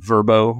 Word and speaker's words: Vrbo: I was interested in this last year, and so I did a direct comparison between Airbnb and Verbo, which is how they like us to pronounce it now.